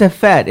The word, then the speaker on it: effete: It's a fet. It's pronounced a fet. – Is it really? – Yeah, you goddamn high school dropout. It's a fet. E-F-F-E-T-E.